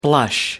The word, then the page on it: blush: American English